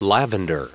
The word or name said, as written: lavender